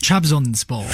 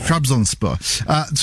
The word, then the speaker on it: Trabzonspor: So, Trabzonspor, am I saying that right? – Trabzonspor. – Trabzonspor.